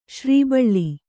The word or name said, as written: ಶ್ರೀಬಳ್ಳಿ